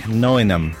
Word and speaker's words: Nottingham: I also feel I should add the more authentic pronunciation of Nottingham has pretty much no T's and no G. Try saying it like Noinum and you'll fit right in.